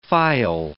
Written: phial

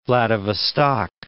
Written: Vladivostok

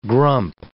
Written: grump

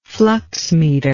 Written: fluxmeter

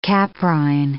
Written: caprine

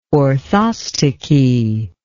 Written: orthostichy

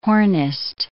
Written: hornist

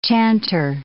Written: chanter

